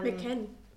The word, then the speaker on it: McCann: Kurz äh, für die Leute, die sich, die jetzt vielleicht nicht sofort mit dem Namen was verbinden, Madeleine McCain. – Ähm, McCann.